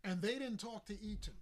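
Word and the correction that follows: Entin: when he went there. Enton. Let me say his name right. Brian Enton.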